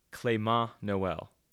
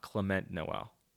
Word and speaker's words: Clément Noël: The French slalom skier is Clément Noël, not Clement Noël. He's not a Clementine fruit.